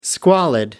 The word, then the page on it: squalid: American English